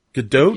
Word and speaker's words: Gadot: Oh, you said it right. – Not to split hairs, but he did not say it right. – That's how I've heard it's supposed to be pronounced. – Gal Gadot. I've heard her pronounce it. – Gadot. – Gadot. – Yes. – Oh, not dot dot.